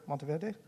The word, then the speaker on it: Monteverde: Did I say that right? Monteverde, and also in Carmelo. Okay, good. Muy bien.